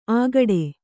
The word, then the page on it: ಆಗಡೆ: Kannada; āgaḍe